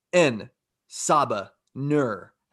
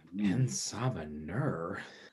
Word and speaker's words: En Sabah Nur: Do you have a vague idea about N Saba Nur? And I hope I'm pronouncing that right. N Saba Nur. – N Saba Nur.